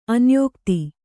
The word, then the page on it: ಅನ್ಯೋಕ್ತಿ: anyōkti